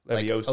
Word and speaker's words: Levioso: Levioso. – Like Oso.